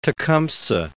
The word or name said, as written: Tecumseh